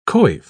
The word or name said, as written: coif